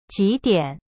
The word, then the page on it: 几点: jidian